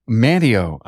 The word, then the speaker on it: Manteo: Lighthouse Auto Repair in Manteo? – Manteo.